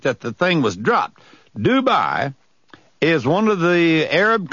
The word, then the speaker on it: Dubai: It's spelled D-U-B-A-I. I remember the first time I saw it, we had to do some checking to know how to pronounce it. It's pronounced just Dubai, okay?